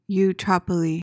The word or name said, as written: eutrapely